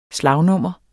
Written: slagnummer

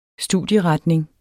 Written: studieretning

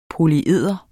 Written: polyeder

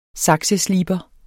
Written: SAKSESLIBER

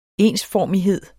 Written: ENSFORMIGHED

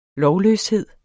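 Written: lovløshed

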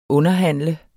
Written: underhandler

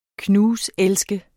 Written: knuselske

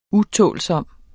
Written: utålsom

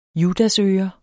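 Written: JUDASØRE

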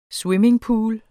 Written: swimmingpools

